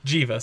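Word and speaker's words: Geva: Giva, soft G.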